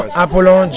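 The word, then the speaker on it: Jean Appolon: And spell Jean Apollon, J-E-A-N, Apollon, A-P-P-O-L-O-N.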